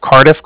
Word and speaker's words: Cardiff: Can you let Hugh know that from the Bank of Cardiff called? – Bank of what? – Cardiff. I know it sounds a little funny over the phone. It's spelled C-A-R-D-I-F-F, as in Frank.